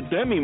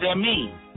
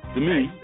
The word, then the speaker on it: Demi: This young man, now he was married to Demi Moore, okay? – It's pronounced Demi. – Demi.